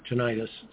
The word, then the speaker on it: tinnitus: So just to give you an example, the most common disability, service-connected disability in the veteran system is a condition called tinnitus, or tinnitus, sometimes pronounced, which is a bad ringing in the ears, basically.